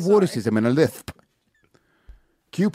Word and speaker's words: rhotacism: It's roticism. Speech impediment is defined by a lack of ability or difficulty pronouncing the R sound. Yes. Rhotacism. They can't even say their own disease name.